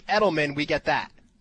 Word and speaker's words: Edelman: Julian Edelman.